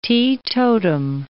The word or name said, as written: teetotum